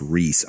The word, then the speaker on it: Rysz: I think it's pronounced Reese, R-Y-S-Z.